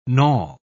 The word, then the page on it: knawel: British English